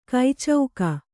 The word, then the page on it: ಕೈಚೌಕ: kai cauka